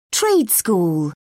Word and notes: trade school: British English

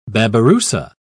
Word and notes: babiroussa: British English

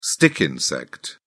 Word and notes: stick insect: British English

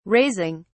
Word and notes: raising: British English

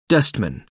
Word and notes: dustman: American English

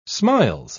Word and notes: smiles: British English

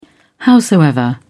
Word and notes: howsoever: British English

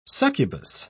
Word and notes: succubus: British English

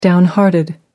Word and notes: downhearted: American English

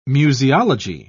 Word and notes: museology: British English

